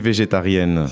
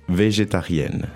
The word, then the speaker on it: végétarienne: So it's végétarienne. – Okay, and because this is a woman, you pronounce the N at the end, right? – Yeah.